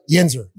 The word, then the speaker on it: Yinzer: Yinzer. Did I say that right? – Yinzer.